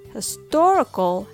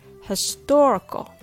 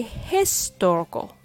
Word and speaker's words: historical: History has the stress on his. Historical has the stress on tor. History, historical. In English, the rhythm of stressed and unstressed syllables are extremely important. Because when you say historical, it sounds off. When you say his story, that doesn't sound good either.